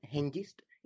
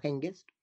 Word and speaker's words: Hengest: Y por cierto, es por esa I por la que pronunciamos una G palatalizada en lugar de una G velar, Hengest, y no Hengest.